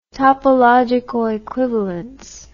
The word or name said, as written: topological equivalence